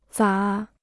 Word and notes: 咋: zǎ

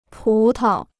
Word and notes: 葡萄: pú tao

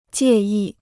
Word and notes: 介意: jiè yì

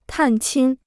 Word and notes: 探亲: tàn qīn

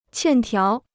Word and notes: 欠条: qiàn tiáo

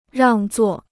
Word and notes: 让座: ràng zuò

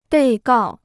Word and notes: 被告: bèi gào

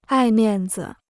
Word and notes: 爱面子: ài miàn zi

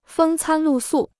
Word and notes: 风餐露宿: fēng cān lù sù

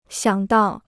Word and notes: 想到: xiǎng dào

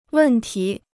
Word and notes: 问题: wèn tí

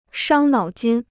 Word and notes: 伤脑筋: shāng nǎo jīn

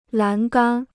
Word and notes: 栏杆: lán gān

